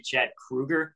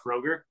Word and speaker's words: Kroeger: There was an interview recently where Chad Kroeger was like, which also that blew my mind. You know, his name is actually Chad Kroeger, not Kroger.